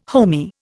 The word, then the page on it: homey: Indian